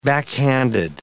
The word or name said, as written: backhanded